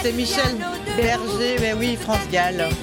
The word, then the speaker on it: France Gall: Mais tu l'as mieux prononcé. Moi j'ai dit France Sac. – Ah, tu as dit France Sac. – Ouais, France Sac, c'était une erreur bête.